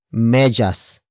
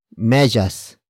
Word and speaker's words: medyas: It is the focus marker that introduces the main topic of the sentence that is becoming yellow, and that is medyas. Medyas.